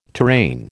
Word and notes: terrain: American English

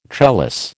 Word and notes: trellis: American English

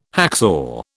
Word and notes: hacksaw: British English

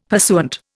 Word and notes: pursuant: Indian

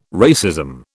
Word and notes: racism: British English

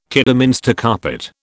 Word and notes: Kidderminster carpet: British English

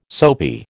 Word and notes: soapy: American English

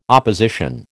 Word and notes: opposition: American English

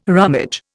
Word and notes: rummage: Indian

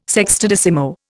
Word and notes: sextodecimo: Indian

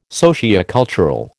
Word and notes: sociocultural: American English

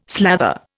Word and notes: slather: Indian

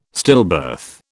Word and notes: stillbirth: British English

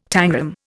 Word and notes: tangram: Indian

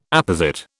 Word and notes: apposite: British English

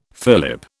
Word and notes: fillip: British English

